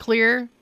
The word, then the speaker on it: Xlear: I got this awesome one at the store that's called Clear, and it's spelled X-L-E-A-R.